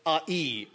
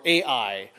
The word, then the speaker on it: Ai: And then there's Ai, or Ai, depending on how you're pronouncing it.